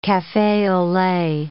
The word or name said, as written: cafe au lait